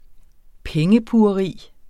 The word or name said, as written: pengepugeri